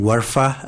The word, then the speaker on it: Warfa: My name is Muhyiddin Warfa. Our last name is spelled W-A-R-F-A.